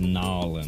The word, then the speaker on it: New Orleans: In the year 1827, something happened in New Orleans for the first time, or New Orleans. – Nolens. – Nolens. – I've been told that you should pronounce New Orleans Nolens. – Nolens. – N-A-W-L-E something something.